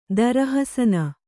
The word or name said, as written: ದರಹಸನ